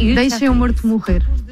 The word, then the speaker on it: Deixa-me Morto Morrer: There is a track called Deixa-me Morto Morrer. Am I pronouncing that? Am I butchering that pronunciation? – It's in Portuguese. It's very hard to pronounce Portuguese, but it's almost perfect.